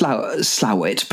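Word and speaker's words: Slaithwaite: I was thinking, I also Slathwaite. I've never known how to pronounce that. – Well, I think it's Slowit, but then if you talk to the people who really know the waterways there,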